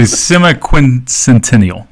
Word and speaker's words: semiquincentennial: It is the semiquincentennial. – Semiquincentennial. – Yes. – So you get, you kind of like quarter Roman numerals, you start years. Going backwards. – Semiquincentennial. – Yeah. – That's how you say it.